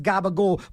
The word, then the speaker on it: gabagool: Yeah, it's pronounced gabagool, but it's a capicola.